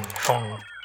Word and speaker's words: Phaune: I mean, phone radio. It's spelled P-H-A-U-N-E. But it's pronounced phone.